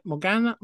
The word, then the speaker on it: Morgana: As she dreams of becoming a mermaid, while Ursula's evil sister, Morgana, I think that's how you say it. Is that how you say it? – Morgana, plots to seek revenge for Ursula's death and to take over the sea herself.